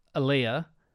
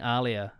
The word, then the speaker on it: Aaliyah: Now, I say her name incorrectly. I apologize in the past. It's Aaliyah. I think I was saying Aaliyah.